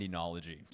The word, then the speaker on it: oenology: Enology, but it's spelled O-E-N-O-L-O-G-Y. – But it's pronounced with an E? – Yeah, that O-E is like a enology.